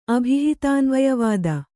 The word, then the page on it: ಅಭಿಹಿತಾನ್ವಯವಾದಿ: abhihitānvayavādi